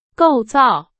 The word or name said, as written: 构造